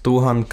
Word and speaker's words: Dogan: Yo, äh, mein Name ist Dohan Kabadrie, Dogan geschrieben, aber Dohan ausgesprochen. Ich bin Nachwuchsschauspieler aus Berlin-Spandau, bin 19 Jahre alt und ähm, genau, das bin ich.